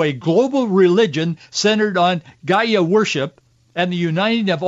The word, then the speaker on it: Gaia: It's spelled G-A-I-A. It's pronounced different ways, but mostly people in English pronounce it Gaia.